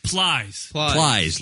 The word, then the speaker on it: Plies: B-L-I-E-S? – Yes. – Plies. – Like flies, but with a P.